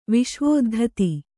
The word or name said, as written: ವಿಶ್ವೋದ್ಧತಿ